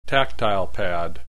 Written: tactile pad

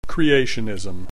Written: creationism

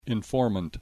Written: informant

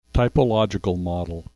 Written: typological model

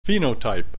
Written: phenotype

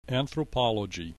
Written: anthropology

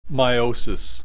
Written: meiosis

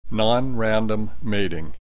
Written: nonrandom mating

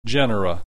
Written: genera

